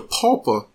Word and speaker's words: pupa: Now, with this exhibition here, you see, it goes on to describe the egg phase, the larval phase, the pulpa stage. Well, P U P A. Sometimes the way I've heard uh, it stated, it seems like it's one syllable. I'm saying it is two syllables.